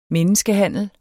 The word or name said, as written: menneskehandler